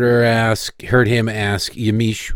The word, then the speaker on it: Yamiche: Of course, that is Yamiche, is apparently how you pronounce it.